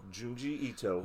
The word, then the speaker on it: Junji Ito: Junji Ito, who's a famous horror manga artist.